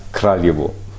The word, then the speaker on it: Kraljevo: Ich grinse über die Aussprache. – Die da wäre Kraljevo? – Kraljevo. Aber es war schon ganz gut.